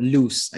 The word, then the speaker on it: Luse: Am I pronouncing oh, it right? – Loose, I think. L U S E. – I think it's loose.